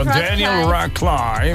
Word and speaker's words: Radcliffe: No sé pronunciarlo bien, pero es Daniel Radcliffe, ¿no? – Sí, señora, muy bien Daniel dicho. Radcliffe. – Radcliffe.